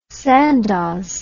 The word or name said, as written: sandoz